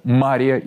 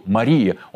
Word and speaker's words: Мария: В Марьино Стояние поминается Мария Египетская. Ударение на первый слог, так как на второй – Мария, он делается только в отношении Пречистой Девы Богородицы. В юности Мария была блудницей,